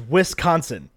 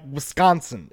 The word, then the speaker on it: Wisconsin: Everybody else says Wisconsin, and you're like, Wisconsin. It's like one, like, SC syllable there together.